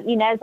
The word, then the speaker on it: Inez: Inez, I-N-E-Z.